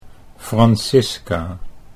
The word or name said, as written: Francisca